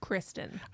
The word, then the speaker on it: Kristen: I don't know if you've know about her, but her husband has this work, and it's like, talk about Kirsten Bell if you're gonna bring up Kirsten Bell. Don't define Kristen. – I was gonna say, we saying her name wrong?